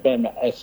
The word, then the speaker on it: PMAAS: Kalau kita biasanya untuk pengenalan awal itu PMAAS ya. PMAAS.